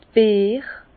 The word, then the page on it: d' Beer: Bas-Rhin; Herrlisheim